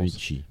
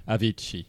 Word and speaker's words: Avicii: Avicii. Avicii.